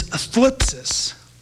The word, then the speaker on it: thlipsis: Now, tribulation is a word in Greek that's pronounced kind of weird. It's a TH sound and then an L. So if you transliterate it in English, it's spelled T-H-L. And you kind of have to do this with your tongue on your lips. It's thlipsis.